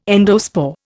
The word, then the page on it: endospore: Indian